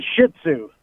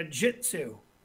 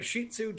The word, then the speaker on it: Shih Tzu: You know, when I first met my wife, Debbie, you know how she used to pronounce Shih Tzu? – No. – She used to say a Jiu Tzu.